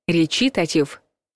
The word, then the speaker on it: речитатив: Речитатив. Неправильный вариант. Речитатив. Правильный вариант.